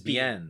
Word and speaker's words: Biehn: Is it Biehn or is it Biehn? – No, it's Biehn.